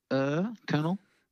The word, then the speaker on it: colonel: Isn't it like the word colonel? It's spelled colonel, but it's said colonel. – Well, if you're Australian, you say colonel. It's a C-E-R.